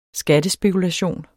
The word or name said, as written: skattespekulation